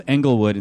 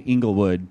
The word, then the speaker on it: Englewood: Might be pronounced Englewood instead of Inglewood.